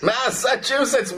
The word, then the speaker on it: Massachusetts: Massachusetts, well pronounced on top of it.